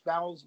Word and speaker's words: Bowles: Bowles. – Yeah. Bowles.